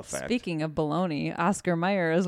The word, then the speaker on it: Oscar Mayer: it's always been Oscar Mayer, but it's it's pronounced Oscar Mayer.